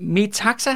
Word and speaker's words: Metaxas: Nå, me- jeg har lyst til at kalde ham Meta, men det er ikke sådan, at udtale staves m E-T-A-X A-S.